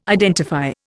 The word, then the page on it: identify: Indian